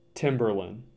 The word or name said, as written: Timberlane